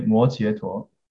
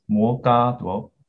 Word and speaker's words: 摩揭陀: So 佛是常在舍卫国和摩诃陀曼嘎达摩摩摩 My teacher, if you listen to the recording, he will say 摩揭陀. But this word actually can be pronounced as 摩嘎陀 also.